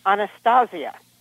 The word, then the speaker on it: Anastasia: and my name was changed to Anastasia. That was the way it was pronounced, very much a Latin way of pronunciation.